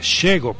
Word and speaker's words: Shego: Shego, porque me estaba confundiendo con otras, pero no, vamos con ellas que nos van a presentar el primer estreno de esta selección. Shego, se escribe S-H-E-G-O, Shego.